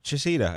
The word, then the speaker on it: Chaseedaw: So, Chisita, I said that right, correctly, finally? – Yes, you did.